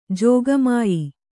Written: ಜೋಗಮಾಯಿ

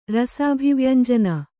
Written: ರಸಾಭಿವ್ಯಂಜನ

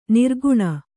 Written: ನಿರ್ಗುಣ

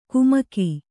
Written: ಕುಮಕಿ

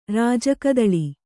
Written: ರಾಜಕದಳಿ

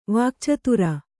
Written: ವಾಕ್ಚತುರ

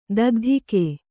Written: ಥಕಥಾಕು

